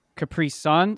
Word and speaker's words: Capri Sun: Capri Sun. So the emphasis is on the sun. – Well, the emphasis is on the last syllable.